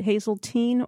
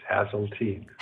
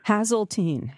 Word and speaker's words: Haseltine: Is it Haseltine or Tyne? – It's neither. It's Haseltine. – Haseltine.